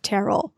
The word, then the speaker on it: Terrell: A quick word on the pronunciation. I have to tell you, people come down on both sides. But the filmers of several documentaries have come down on the side of Terrell. So you'll hear Terrell. I imagine people will know to whom you refer, but they pronounced it Terrell. So that is how we will proceed.